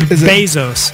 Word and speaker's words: Bezos: Bezos? How do I say that? – Bezos.